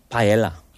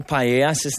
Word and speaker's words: paella: Which country is famous for paella? – Paella.